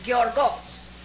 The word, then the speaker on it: Gyeorgos: That is G-Y-E-O-R-G-O-S. It is Greek pronunciation, Giorgos. Seris, C-E-R-E-S.